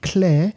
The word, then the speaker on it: Kler: It's called Claire. That's spelled K-L-E-R, and it means clergy in Polish.